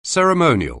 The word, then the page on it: ceremonial: anglais